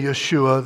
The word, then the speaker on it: Yeshua: Now, Jesus, and sometimes we say Yeshua, the Hebrew pronunciation, came to show us who the Father is.